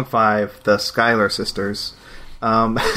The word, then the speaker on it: Schuyler: The sh- the sh- Sky- sh- Schuyler, the Schuyler, Schuyler sisters.